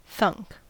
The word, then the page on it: thunk: American English